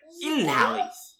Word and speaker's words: Gyllenhaal: So he says it really is pronounced like Ilhali, Ilhal. And uh, Jake says, "Yeah, that's right." He says it's interesting. The only two places I see it pronounced correctly are Sweden and IKEA.